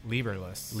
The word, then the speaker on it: Liberles: Well, first tell us, how do you say your last name? – Liberles. – Liberles. – Yep. – Kept wanting to say it. Liberales.